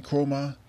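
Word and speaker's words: Kromah: Osmani Chroma. Osmani spelled O-U-S-M-A-N-E. Chroma spelled K-R-O-M-H.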